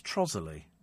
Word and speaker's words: Trottiscliffe: Spelt Trottiscliffe, pronounced Trozzerly.